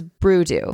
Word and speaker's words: Brudeau: It rhymes with voodoo. So it's Brudeau.